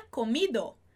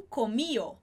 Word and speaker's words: comido: Comer seria comido, e aí eles pronunciariam comio, comio, com esse I mais forte, comio.